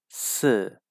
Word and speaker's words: 四: As you may know, four is pronounced as si in Chinese, and it sounds like the word that means death which is 死.